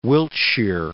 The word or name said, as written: Wiltshire